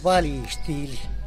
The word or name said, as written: balitxily